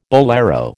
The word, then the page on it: bolero: American English